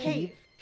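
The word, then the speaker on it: Kyiv: So, and Kiev, is it Kiev? How do you pronounce it again? – Cave. – Cave. Okay.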